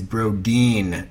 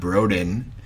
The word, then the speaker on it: Brodin: Their name is Brodeen, not Broden. Even though, in my defense, it is spelled B R O D I N without the E. So it, I, I, I pronounced it like it's spelled.